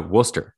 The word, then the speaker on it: Worcester: It'd be like saying Leicester, which I just think, you know, that's people learn, like, you know, Worcester. Worcester has about 50 silent letters in it.